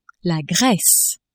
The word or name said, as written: Grece